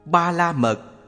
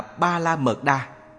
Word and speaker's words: Ba la mật: Ba la mật, phiên âm từ phạn ngữ là paramita, cũng đọc là ba la mật đa.